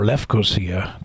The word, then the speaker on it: Lefkosia: We were, I'm inserting the fact that those of us who were waiting on Cyprus in the capital city of Nicosia or Lefkosia, the proper pronunciation, were kind of holed up in a dormitory on a campus on the outskirts of Lefkosia.